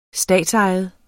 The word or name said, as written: statsejet